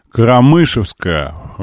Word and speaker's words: Карамышевская: Уголок Дурова, Карамышевская, поправляет ударение на второе, на второе.